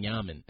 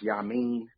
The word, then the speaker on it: Yamin: Yamin Semali, King Yamin. Is there any like future collab album with you guys? – Yeah, just for, it's pronounced Yamin.